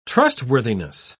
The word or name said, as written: trustworthiness